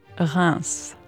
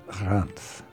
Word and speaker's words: Reims: And then you have to pronounce the S at the end, which usually we don't. We don't say Paris, we say Paris. – Right. – But we say Reims, so it's the exception.